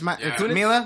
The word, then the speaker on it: Mila Kunis: Mila Kunis. – Let's have Missy Elliott, too. – Did I say that right? – Yeah, it's Mila, Mila, Mila, Mila.